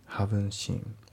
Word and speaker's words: haven't: で、あと発音ですけど、 haven't っていうのは t が抜けますね。 haven't seen。